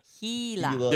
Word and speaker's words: Gila: Gila. – The G Gila. Is pronounced as Gila. – It's a silent G.